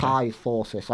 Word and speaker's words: kyphosis: Kyphosis, like osmosis, but with a K.